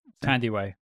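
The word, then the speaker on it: Thandiwe: How do you pronounce her first name? Tandiway. – Tandiway Newton. Okay, so I've been saying Thandi Newton's this whole time.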